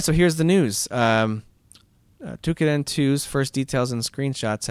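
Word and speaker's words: Toukiden: Shiren the Wanderer, and I'm gonna mispronounce this next one. Tokiden 2, or Night in the Woods. Which one do you want to hear about? – Tukiden. – Is that how you say it? Tukiden? – That's how I say it.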